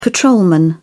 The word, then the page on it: patrolman: British English